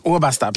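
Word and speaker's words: Obatzda: Jo, här ska vi se så att jag uttalar rätt. Obasta. – Obasta, precis.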